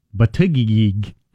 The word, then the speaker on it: Buttigieg: Hickenlooper Buttigieg. – Is that the way you say his name? – Yeah. – Buttigieg.